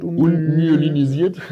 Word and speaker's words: unmyelinisiert: Genau, das sind über 70 Prozent, machen unmyelisierte Axone aus. – Das war ein ähm, bisschen genuschelt, unmyelinisiert. – Ja, unmyelinisierte Axone, ja. Bitte ein bisschen auf die Aussprache achten, Bijan.